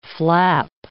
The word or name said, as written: flap